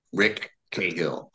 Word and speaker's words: Cahill: Yeah, I wanted another hat tip to Chandler, Raymond Chandler, RC, and I want to have that hard C sound. Rick Cahill, wanted to be short, and I really wanted the hard C to kind of, you know, hit.